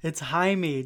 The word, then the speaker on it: Jaime: Steve, it's Jaime. Just pronounce it with an H. It's Jaime.